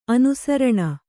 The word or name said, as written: ಅನುಸಾರಿಣಿ